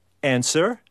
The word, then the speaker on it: answer: Or answer? – W. – W, yeah.